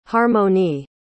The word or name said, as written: harmoni